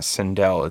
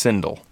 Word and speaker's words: Sindel: Except the fact that they don't really, her name's not Sindel, it's Sindel.